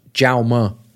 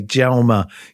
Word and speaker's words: Jaume: Stefanos Tsitsipas and Jaume Munar. – No, it's pronounced Jaume. – Okay, Jaume. See, I speak Spanish, but I don't speak Catalan, and that's my excuse.